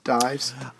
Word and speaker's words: Dies: Senator Martin Dees. No, Congressman Martin Dees. – Dies. – Uh, dies. – Junior. You told me it's pronounced Dies. Junior.